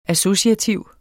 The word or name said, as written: associativ